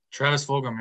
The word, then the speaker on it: Travis Fulgham: And we're got, oh my god, I don't even know if I can pronounce this name, Travis Fulgham. Is that right? – Travis Fulgham. Yeah, Fulgham.